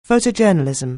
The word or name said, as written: photojournalism